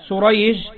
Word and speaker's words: سريج: هنا كلمة شريح وكلمة سريج لو كتبناهما دون نطق أصبحت سريج وشريح واحد، الاثنين في الكتابة واحد، فهما مؤتلفان متفقان في الخط، وأما في النطق فمختلفان. هذا شريح وهذا سريج.